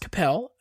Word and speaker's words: Coppell: It's pronounced Coppell.